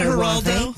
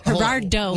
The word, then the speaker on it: Gerardo: Now, is that Geraldo? – Gerardo.